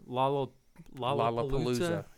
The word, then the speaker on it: Lollapalooza: He organized Lollapalooza.